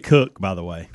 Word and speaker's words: Koch: Christina Cook and Jessica Mir. – Even though it's spelt Koch or Coke, it is Cook.